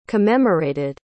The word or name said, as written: commemorated